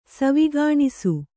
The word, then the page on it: ಸವಿಗನಸು: saviganasu